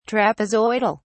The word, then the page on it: trapezoidal: British English